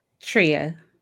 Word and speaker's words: Treya: Treya.